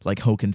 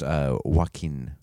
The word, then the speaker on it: Joaquin: It's pronounced Joaquin. – Oh, well, yeah. In our country we say Hoken.